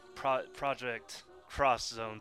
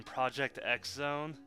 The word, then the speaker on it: Project X Zone: I mean, I was expecting Project Cross Zone 2, which I just realized for like the past couple years I've been saying that wrong. I kept saying Project X Zone. No, it's Project Cross Zone, and I'm like, holy shit, why didn't I see that?